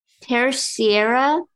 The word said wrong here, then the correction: Terceira: I definitely said that wrong, but you want to take a shot at it? – Okay. Pronouncing it or the song? – Pronouncing it. – Terceira. – Wow. – Oh, it's Portuguese.